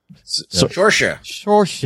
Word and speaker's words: Saoirse: I was going to say Cerise, or how do you say her name? – Cersei? No. – Saoirse. – Yes, it's said in a way that's not how it's not spelled, but she's a really good actress.